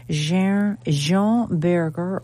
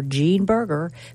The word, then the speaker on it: Jean Berger: Jean Berger, or Gene Berger, if you want to say it like they say in the United States.